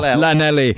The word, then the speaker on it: Llanelli: Llanelli. Sorry, my pronunciation. – Yeah. No, it was good. It was good. – Llanelli.